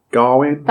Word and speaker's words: Gawain: So in essence, is it Gawain? I forget how they pronounce it in the film.